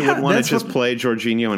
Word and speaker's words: Jorginho: I think it's Jorginho. – Jorginho, thank you.